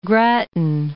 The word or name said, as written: Grattan